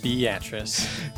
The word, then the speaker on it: Beatrice: Yes, that's how the locals say it, Beatrice.